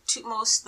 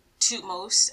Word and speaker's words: Thutmose: And keep in mind, there are many interpretations of how to say these people's names, because I've always heard Thutmose as Thutmose, and that's how I've always said it. But then there's also people who say Thutmose, Thutmose, Thutmose the first and his wife Ames.